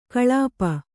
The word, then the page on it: ಕಳಾಪಿ: kaḷāpi